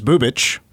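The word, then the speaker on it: Bubic: But also on the mound tonight is Troy's favorite pitcher, Chris Bubich. I got the name right. – You did.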